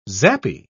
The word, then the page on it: zappy: British English